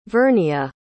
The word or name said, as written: vernia